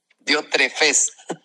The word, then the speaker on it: Diótrefes: Entonces, el caso de Diófretes, ¿cómo se pronuncia así? Diótrefes. El caso de Diótrefes es un común denominador dentro de la iglesia.